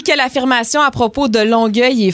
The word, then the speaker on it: Longueuil: Oui, mais faut le préciser parce que les deux se prononcent Longueuil quand même.